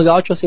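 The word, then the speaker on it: gaúcho: Mira, en el plan político específico yo pertenezco a la FAG, la Federación Anarquista Gaucha, porque los gauchos en portugués ponen un acento en la U, gaucho.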